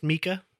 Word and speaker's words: Mika: And it is pronounced Mika. Mm-hmm. I know that a lot of people say Mika. It is Mika, and it took me forever to find an actual pronunciation. It's in the only place I could find it was in an article on the BBC's website from like 2007. – Wow. – And it's like literally the opening line was like Mika, pronounced M-E-E-K-A.